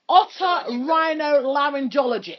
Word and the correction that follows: otorhinolaryngologist: You're not even saying it properly. – I am otorhinolaryngologist. – Otorhinolaryngologist. – See? – Oh, gosh.